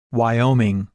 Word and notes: Wyoming: English

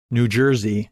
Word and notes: New Jersey: English

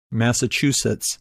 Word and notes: Massachusetts: English

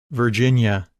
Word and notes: Virginia: English

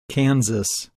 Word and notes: Kansas: English

